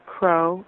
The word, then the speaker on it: Krogh: and it's a Danish study by Camilla Krogh, I believe is the pronunciation. It's K-R-O-G-H.